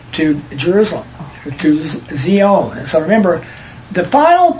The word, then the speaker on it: Zion: The correct pronunciation is Zion. Like Z and the zebra. – We hear Zion. – Yeah, when everybody says Zion, it's not, it's Zion, according to the Hebrew.